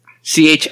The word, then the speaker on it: Chanukkah: Chanukkah is Hanukkah also? – Yeah. That's if you want to do like the more Hebrew pronunciation of Hanukkah.